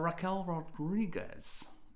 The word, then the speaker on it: Raquel Rodriguez: At number 10 we've got Raquel Rodriguez.